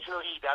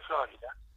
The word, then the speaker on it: Florida: Florida, non si dice Florida.